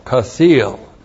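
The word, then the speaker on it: chasil: C-H-A-S long I-L, Kasil. And this comes from the Hebrew verb kasal, Looks like that. C-H-A-S-A-L.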